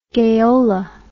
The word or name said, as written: gayola